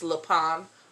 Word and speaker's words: Lupin: It looks like Lupin, but it's not. It's pronounced Lupin, okay, because it's French.